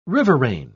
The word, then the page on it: riverain: British English